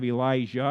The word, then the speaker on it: Elijah: But then when you get to Elisha, you have Elijah and Elisha. How do you know the difference? So we pronounce Elijah with a hard J. It's Elijah. Say it.